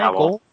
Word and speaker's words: Gowell: Gowell.